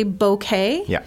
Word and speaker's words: bouquet: And the pronunciation here is we generally say bouquet, even though it's B-O-U, two letters there.